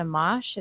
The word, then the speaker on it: Amash: Hey guys, did you hear about Republican Justin Amash? Is that how you say it? – Yeah.